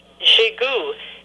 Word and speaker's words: Jegou: And then I have a French name because I'm Canadian, French-Canadian, so the last name is J-E-G-O-U, Jigou, and it's J-E-G-O-U.